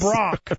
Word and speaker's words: Brock: I mean, anything with Brock, right. Because it rhymes with smock.